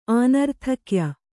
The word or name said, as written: ಆನರ್ಥಕ್ಯ